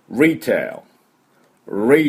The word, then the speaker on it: retail: で、このリテール、リテール、リテールとかいうふうに言いますけども、ちょっと発音が違います。どんなふうになるかというと、リテール、リテール。これ、 L、最初 R で始まって L で終わるっていう、日本人にとってはかなり難しい、ところですが、舌を引いといてね、 retail、テールって言ってもいけません。tail、 L で終わります。